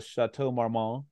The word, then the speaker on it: Chateau Marmont: She was Mandy May, and she's summoned to the hotel, the Chateau Marmont. I don't know if I'm saying it right. – Yeah, you can say the T.